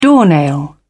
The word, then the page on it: doornail: British English